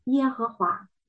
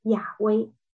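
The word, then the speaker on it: Yahweh: 到了 16 世纪，有神学家提出把 Adonai 它的元音间隔插入放在神的名的辅音上，混合成耶和华，于是读作耶和华。现在学者们认为可能正确的读音应该是亚威。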